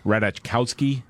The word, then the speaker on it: Ratajkowski: Yeah, Ariana Grande, then of course Kim Kardashian, and now never clear on how to pronounce her last name. Emily Ratajkowski. – I believe that's correct, yes.